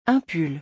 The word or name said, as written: un pull